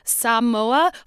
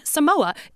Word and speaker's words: Samoa: It had the correct pronunciation of Samoa, followed by a white mm. person saying Samoa.